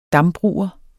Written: dambruger

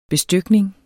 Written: BESTYKNING

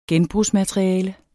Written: genbrugsmateriale

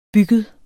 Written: bygget

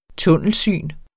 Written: tunnelsyn

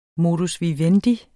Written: modus

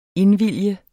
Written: indvillige

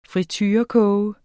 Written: friturekoge

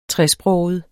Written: tresproget

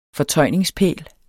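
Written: FORTØJNINGSPÆL

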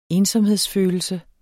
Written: ensomhedsfølelse

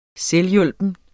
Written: selvhjulpen

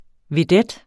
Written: vedets

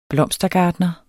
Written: blomstergartner

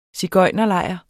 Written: sigøjnerlejr

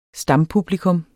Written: stampublikum

